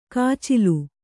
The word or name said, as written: ಕೊಚ್ಚಲು